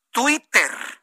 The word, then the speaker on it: Twitter: Twitter, sí, así mire, con toda la T, la I, la doble T, bien pronunciada. Twitter.